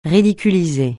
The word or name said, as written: ridiculiser